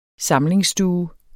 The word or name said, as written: samlingsstue